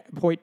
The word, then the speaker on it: Hoyt: Do you think like they're letting people like Hoyt, by the way, if you forget his name, rhymes with exploit.